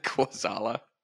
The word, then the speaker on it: Kwaczala: Kwaczala.